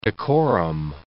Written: decorum

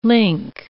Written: link

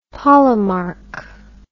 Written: polemarch

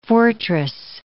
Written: fortress

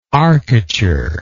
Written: arcature

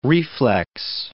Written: reflex

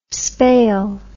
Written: spale

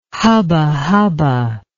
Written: hubba hubba

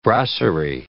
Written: brasserie

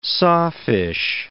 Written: sawfish

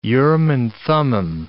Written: Urim and Thummim

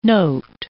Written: note

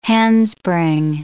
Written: handspring

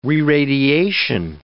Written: reradiation

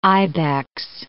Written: ibex

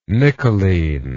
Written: nickeline

